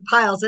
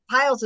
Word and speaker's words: Pyles: And her name is Ashley Piles. And am I Piles is how you pronounce it, right? P-Y-L-E-S. – That's right, uh-huh.